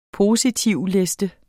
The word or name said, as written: positivliste